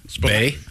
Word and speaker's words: bae: Bay. – Yeah. – I just thought it was the first half of Baby for a while. – Baby. – Well, it's spelled BAE, right?